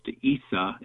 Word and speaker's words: Isa: His name is pronounced Isa in Arabic.